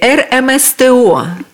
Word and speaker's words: РМСТО: Елена, расскажите в целом о направлениях деятельности РМСТО. Да, я правильно сказала аббревиатуру? – Да, все правильно.